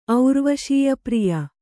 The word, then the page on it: ಔರ್ವಶೇಯಪ್ರಿಯ: aurvaśeyapriya